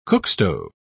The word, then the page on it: cookstove: British English